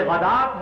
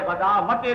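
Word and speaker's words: الغداة: یہ غدات ہے، غداوتے نہیں ہے، واو جو ہے یہاں پر بالکل اس کا کوئی تلفظ نہیں کیا جائے گا، یہ صرف لکھنے میں آ رہی ہے۔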